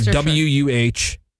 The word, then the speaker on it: Worcestershire: I gave you the legit proper pronunciation. – What is it? – Wait, that's how you say it? – Yeah. Worcestershire. – Worcestershire. – Yeah. – Worcestershire. – Like WUHSTUSHR.